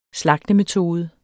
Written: slagtemetode

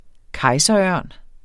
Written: kejserørn

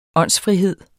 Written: åndsfrihed

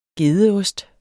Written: gedeost

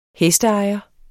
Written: hesteejer

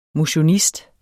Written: MOTIONIST

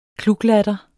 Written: kluklatter

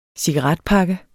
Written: cigaretpakke

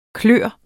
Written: klos

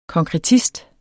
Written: konkretist

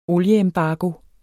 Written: olieembargo

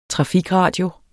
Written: trafikradio

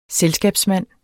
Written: selskabsmand

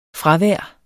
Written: fravær

